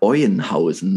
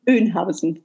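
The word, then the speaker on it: Oeynhausen: übrigens in Bad Euenhausen, ne? – Öhnhausen.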